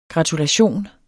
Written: gratulation

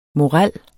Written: morel

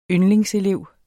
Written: yndlingselev

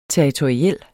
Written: territoriel

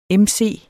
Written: mc

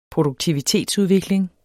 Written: produktivitetsudvikling

